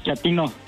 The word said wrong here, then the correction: Chatino: No, chatino. – Es como chato, ¿verdad? Chatino. – Sí, azunero como chato, chatino.